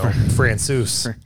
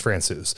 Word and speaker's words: Francouz: Francois? – No, Francouz. You said it wrong this time, sir. – Ooh. – Francois. No, it's Francouz.